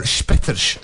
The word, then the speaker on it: Spetters: Yes, Spetters, or Spetters, as we might pronounce it, because it's a Dutch film.